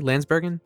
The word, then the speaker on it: Lansbergen: Lansbergen? – Yes.